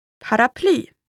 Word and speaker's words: paraply: And finally, for words of French origin, the stress falls on the final syllable. – Affär. – Affär. This means store or shop. – Affär. – Next one is paraply.